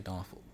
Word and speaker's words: Norfolk: We say Norfolk. – Norfolk. – N-A-W-F-U-C-K. – Norfolk. That's how you say it.